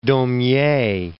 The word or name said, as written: Daumier